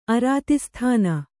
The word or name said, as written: ಅರಾತಿಸ್ಥಾನ